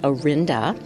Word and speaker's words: Orinda: But another idea was, if you look it up on Google, for instance, it says that the name is pronounced Orinda, like we do, but they say it's of Latin origin and possibly related to the Spanish word oro, meaning gold.